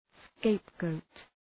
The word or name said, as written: scapegoat